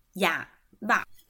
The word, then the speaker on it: yaba: 呃，第一个我们要学的是哑巴，是指爸爸。哑巴是爸爸，所以它的拼音是 y a b a，对，y a b a，哑巴，哑巴就是爸爸的意思哈。